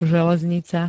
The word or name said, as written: železnica